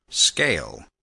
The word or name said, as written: scale